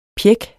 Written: pjæk

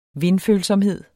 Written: vindfølsomhed

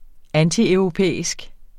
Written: antieuropæisk

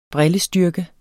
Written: brillestyrke

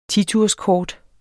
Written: titurskort

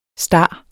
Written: star

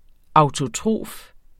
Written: autotrof

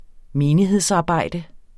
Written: menighedsarbejde

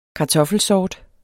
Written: KARTOFFELSORT

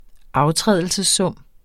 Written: aftrædelsessum

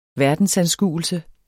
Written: VERDENSANSKUELSEN

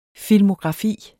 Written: filmografi

